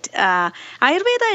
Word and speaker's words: Ayurveda: Tell us about Ayurveda, or am I saying that wrong? – You are right. Ayurveda